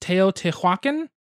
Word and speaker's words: Teotihuacan: Normally, I would call it Tenochtitlan, which I think is the same thing, but it was pronounced Teotihuacan. It was spelled like Teotihuacan.